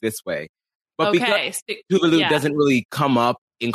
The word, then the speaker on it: Tuvalu: But Tuvalu, also, by the way, I have been saying that wrong. Tovlo, or whatever, is how I was saying it.